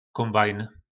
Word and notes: kombajn: po našimu

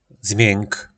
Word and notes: zmjynk: po nasimu

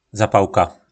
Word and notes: zapalka: po našimu

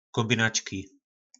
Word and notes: kombinacki: po našimu

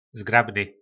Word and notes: zgrabny: po našimu

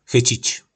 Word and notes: Chycic: po našimu

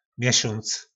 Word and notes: mesonc: po našimu

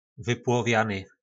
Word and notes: vyplovjany: po našimu

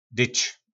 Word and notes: dyc: po nasimu

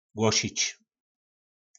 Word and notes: glosic: po nasimu

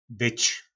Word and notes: Byc: po nasimu

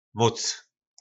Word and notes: moc: po našimu

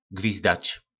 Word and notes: gvizdac: po nasimu

